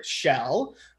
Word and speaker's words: Schell: They can find me on Facebook under Eric Edward Schell. My last name is spelled S-C-H-E-L-L.